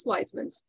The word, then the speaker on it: Weissman: And hopefully I didn't butcher your name too bad. – No, actually, you got it spot on. Most people be like, Weissman. You were spot on.